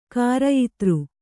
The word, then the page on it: ಕ್ರಯದಾರ: krayadāra